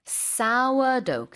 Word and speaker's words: sourdough: Sourdough. 그냥 싸 하시면 됩니다.